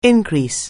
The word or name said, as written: on the increase